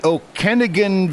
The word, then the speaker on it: Okanagan: And since this is the Okanagan Valley, did I say that right? Yeah, it's valley.